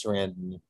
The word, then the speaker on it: Sarandon: I think it's Sarandon. Yeah. – Like Saran Wrap.